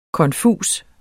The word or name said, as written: konfus